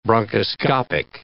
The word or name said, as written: bronchoscopic